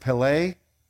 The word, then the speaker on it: Pele: The Hebrew word for wonderful, I pronounce it Pele, and how I remember it is there was a wonderful soccer player back in the day. Remember bicycle kicking Pele from Brazil?